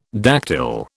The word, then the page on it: dactyl: British English